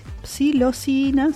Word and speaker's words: psilocina: Los estudios químicos indican que la silobina y en menor grado la sisilocina ¿Lo dije bien? Sí, psilocina, sí.